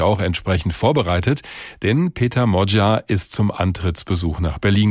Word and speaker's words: Magyar: Sein Name schreibt sich zwar Peter Magyar mit Y, der Nachname wird aber Modja ausgesprochen.